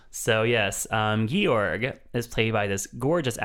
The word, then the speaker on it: Georg: It's G-E-O-R-G, which I figured was just George. – Mm-mm. – But no, it's Georg.